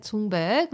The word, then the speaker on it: Thunberg: So I found a video that Greta Thunberg, which is yes.